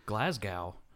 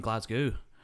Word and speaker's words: Glasgow: He goes, it's like Glasgow. And they're like, oh, you mean Glasgow? I'm like, you sounded American. You called it Glasgow. That's how Americans say it, because that's how it looks.